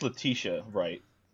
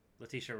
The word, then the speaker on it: Letitia: Is it Letitia or Letita? – Letita. I'm pretty sure I'm butchering it. – I'm pretty sure it's Letitia Wright. – Letitia Wright. Her, yes. – I think it is Letitia Wright. Back. You're not wrong. It's Letitia.